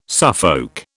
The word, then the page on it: Suffolk: British English